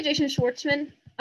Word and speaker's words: Jason Schwartzman: Yeah, Jason Schwartzman.